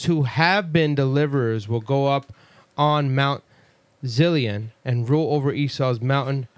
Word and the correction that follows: Zion: Mount Zilion, huh? – I said it wrong? – Yeah, I think it's Mount Zion. – Oh, that was supposed to be Zion? Come on, bro. – It's definitely supposed to be Mount Zion.